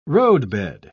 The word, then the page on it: roadbed: British English